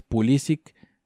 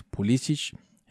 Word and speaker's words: Pulisic: Luego tenemos el caso de Christian Pulisic, que ahora entiendo que su pronunciación es Pulisic, que ha perdido la pronunciación del apellido como, como si fuera de, de estos equipos de los Balcanes, Pulisic. Entonces, pues en una entrevista escuché que, el apellido era Pulisic, para que lo tomemos en cuenta.